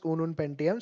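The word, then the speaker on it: Ununpentium: guess उनून उनून पेंटियम guys it's pronounced as उनून पेंटियम spelling is U N U N पेंटियम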